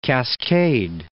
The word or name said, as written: Cascade Range